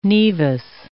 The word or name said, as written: nevus